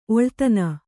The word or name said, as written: ಒಳ್ತನ